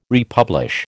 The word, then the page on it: republish: American English